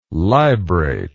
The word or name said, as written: librate